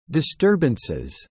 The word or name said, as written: disturbances